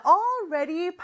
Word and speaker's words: already: 자, already 발음 연습 조금 해볼까요? All 하면서는 사실 혀가 윗니 뒤쪽에 와서 붙어야 돼요. 그러면서 떼면서 ready 이렇게 발음을 하지만 사실 바로 옆에 있잖아요. L하고 R 이 두 철자가. 그래서 가다가 찍지 못하고 오는 경우가 많습니다. Already 이렇게요. 가려는 시도는 해야 돼요. Already 이렇게 발음해 주세요.